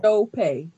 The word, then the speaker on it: Sope: Chope. Like S-H-O-W-P-A-Y. Chope. – The H The H is pronounced, but it's not there. So it's visibly silent. It's spelled S-O-P-E.